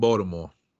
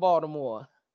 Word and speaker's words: Baltimore: No, I'm not from Baltimore. Did I say it right? – Barely. – Okay. I was trying not to say that hard ass T.